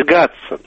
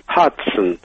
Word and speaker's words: Хадсон: Не «Гадсон» и не «Гудсон» или «Гудзен», а именно это закрепилось за Нью-Йоркской рекой «Хадсон». По-английски, по-американски произносится абсолютно так же, как имя домоправительницы Шерлока Холмса.